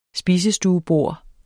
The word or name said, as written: spisestuebord